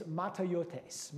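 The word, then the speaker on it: matayotes (ματαιότης): And the way to pronounce this word is matayotes. Matayotes. It almost sounds kind of Japanese, but it's Greek.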